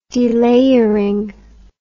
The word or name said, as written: delayering